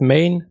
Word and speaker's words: Gmane: Er sagt, the G is silent. Das heißt Main, Mine oder Mine.